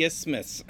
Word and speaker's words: isthmus: Over this, and now this, here's a word I don't know how to pronounce, isthmus.